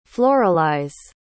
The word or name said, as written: floralize